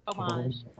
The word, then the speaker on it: homage: Spells it. O-M-A-G-E. Homage. Homage. Because you don't pronounce the H. Because French.